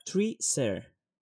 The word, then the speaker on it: Tree Ser: Eh, como digo, la artista eh, Johanna Tarkela, la editorial, es una editorial que se llama Tree Ser. Es de Suiza. Y lo pronuncio así porque el logo y todo va relacionado con árboles y naturaleza. Por eso lo de Tree.